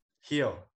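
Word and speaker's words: Gil: Louis Gill. – Heel. – Why did it say Gill? – Well, some things are pronounced differently than the way they're spelled. – Is it actually Hill? – Luis Hill.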